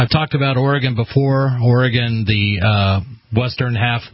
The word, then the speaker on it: Oregon: It's Oregon. It's a schwa. Oregon. Repeat after me. Oregon.